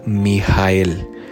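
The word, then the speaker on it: Mijael (מיכאל): buscan el número 48 y en español se pronuncia Mijael. Mijael, tal cual.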